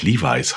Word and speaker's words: Levi's: Früher haben wir Levi's gesagt, mhm. ja, weil er Levi Strauß. Ja. Und dann haben wir irgendwann gelernt, dass es ja eigentlich Levi's heißt.